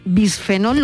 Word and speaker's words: bisfenol: Bueno, hay un cerco a un producto tóxico que es el bifenol, bisfenol, lo digo bien, bisfenol A, que está presente Estivaliz en un montón de envases.